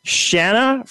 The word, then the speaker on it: Shanna: Shanna from Louisiana.